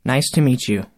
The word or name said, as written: nice to meet you